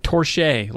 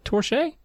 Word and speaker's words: Torche: Torch, but with an E. Okay? Like torche. Like somebody says something, you're like, hey, touche. But don't French? – Say it correctly. Say torche.